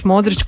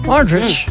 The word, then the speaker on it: Modrić: Ja sam sretna što su komentatori napokon naučili izgovoriti taj Č. Uvijek je pribilo Modrić. – Modrić.